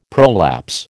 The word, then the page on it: prolapse: American English